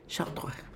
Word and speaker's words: Chartres: Say it. – Chartres. – Very nice. Do you know how that's spelled? – Chartres. – Go on, spell it. – C-H-A-T-U-A. – No. Really good, though. C-H was, and then the A was good. C-H-A-R-T-R-E-S. – Chartres.